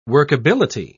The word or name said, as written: workability